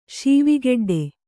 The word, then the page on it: ಸಾವೆಗೆಡ್ಡೆ: sāve geḍḍe